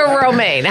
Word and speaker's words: Romijn: Romijn. That's pronounced Romijn, like the lettuce, yeah.